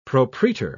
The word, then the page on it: propretor: British English